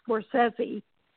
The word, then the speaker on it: Scorsese: In fact, we had to learn that it is pronounced Scorsese, you know, and that's one thing we were sticklers for on TCM is getting the correct pronunciations of names.